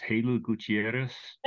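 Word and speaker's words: Jeylú Gutiérrez: Is it pronounced Halo Gutierrez? – That's right, yes.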